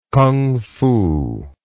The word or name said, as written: kungfu